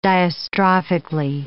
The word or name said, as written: diastrophically